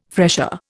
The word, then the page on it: fresher: Indian